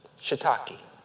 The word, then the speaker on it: shiitake: Loved ones, if you look at that phonetically, that S-H-I-I is shuh. Shiitake is how you say it.